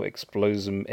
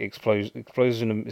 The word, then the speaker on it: Explosm: And you can find this on their channel, Explosum Entertainment. That's sort of like Explosum is spelled EXPLOSM.